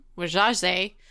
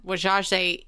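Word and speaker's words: Wazhazhe: that actually is the way that the Osage people say their name. So that is like the correct pronunciation. And it took us like a whole like 3 or 4 minutes to realize that Wajaze is how to say the letters of Osage, like O-S-A, you know, G-E, like, that's how they pronounce it.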